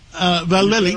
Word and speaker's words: Vallely: Well, Major General Paul Vallely, did I say it right? Vallely. – You're right. Yeah.